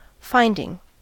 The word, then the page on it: finding: American English